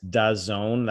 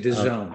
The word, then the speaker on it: DAZN: Um, the most recent offender is Dazone. That's apparently how you pronounce D A Z N. It's actually Dazone.